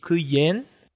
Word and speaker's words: kuyen: E kuyen, que significa lua, tem uma aberta, que é k, e uma fechada, que é yen.